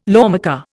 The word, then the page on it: lawmaker: Indian